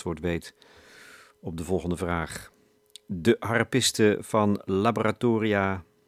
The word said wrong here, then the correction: Laboratorio 600: nou zeg ik het weer. Dat komt omdat het gaat over een harpiste, dus dan is het een Laboratorio 600, zo moet ik de naam uitspreken. Laboratorio 600.